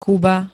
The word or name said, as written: kuba